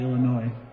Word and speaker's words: Illinois: pronounced usually Illinois. Except in bluegrass when you add S's at will.